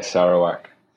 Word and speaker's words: Sarawak: After that, you returned back to Asia with Sarawak. Have I pronounced that correctly? – Yeah, Sarawak.